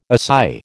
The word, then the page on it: assai: American English